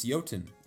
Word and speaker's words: Jotun: I believe that Pure Evil sent me the correct pronunciation on how to pronounce Jotun, and it's Jotun.